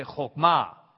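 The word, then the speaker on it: chokmah (חכמה): Chokmah. Chokmah. Two syllables. Chokmah. Only in Hebrew, we tend to accent on the last syllable. So we say chokmah.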